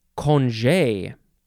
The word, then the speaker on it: congé: Okay, next is congé. Congé or congé. C O N G E, accent on the E, low on the left, high on the right.